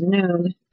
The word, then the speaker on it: Nun: When Yehoshua, the son of Nun, Nun, spelled N-U-N but pronounced Noon,